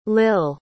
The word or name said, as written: lill